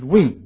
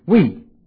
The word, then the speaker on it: oui: You know, the Ouija board is, you spell it O-U-I-J-A. Now, the French word oui means yes. O-U-I is we. I used to call it oi, oi, but it's we.